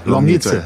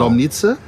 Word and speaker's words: Lomnice: Geboren bist du 1972 im tschechischen Lomnice. Ich hoffe, ich habe das richtig ausgesprochen. – Lomnice. – Genau. Lomnice.